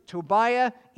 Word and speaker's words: Tobiah: And you pronounce it Tobiah in Hebrew, unless you're fiddling on a roof, in which case it's pronounced Tevya, same name.